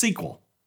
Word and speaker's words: SQL: That stands for Structured Query Language, commonly pronounced SQL.